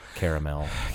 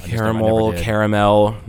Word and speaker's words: caramel: Caramel. – Caramel, not caramel. There's three syllables there, Paul.